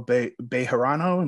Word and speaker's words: Bejarano: I have the esteemed pleasure to interview Geronimo Bejarano.